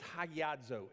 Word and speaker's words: hagiazo: That word is hagiazo in the Greek. It's H-A-G-I-A-Z-O, long O, right?